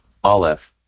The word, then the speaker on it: Aleph: Aleph.